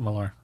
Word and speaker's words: Millar: You actually, how do you pronounce that? – Technically, it's Miller, but we all call it Millar.